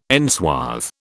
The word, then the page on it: enswathe: British English